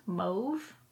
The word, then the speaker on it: mauve: Also, I think mauve is actually supposed to be pronounced mauve.